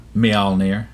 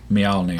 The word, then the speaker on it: Mjolnir: Mjolnir. – Mjolnir? – Mjolnir.